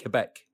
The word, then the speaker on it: Quebec: A riot broke out when she visited Quebec in 1960. Is that how you pronounce it, Quebec? – I think it's Quebec, yes.